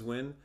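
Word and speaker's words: Nguyen: It's Win. – I never knew how to pronounce that because last name. The spelling throws you off.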